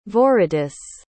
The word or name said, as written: viridis